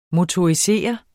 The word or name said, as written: motorisere